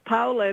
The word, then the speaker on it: Paola: It's Italian. It is Paola, and everybody, I just say, call me Paola, because then they go into Paola and all these other different pronunciations.